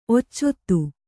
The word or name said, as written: ಒಚ್ಚಿತ್ತು